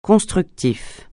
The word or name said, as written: constructif